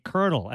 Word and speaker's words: colonel: Ted just had a one-word answer to our question, colonel, like the rank in the army, C-O-L-O-N-E-L. There's not an R in sight, and we say colonel, and that's got a trilingual story you write in the book.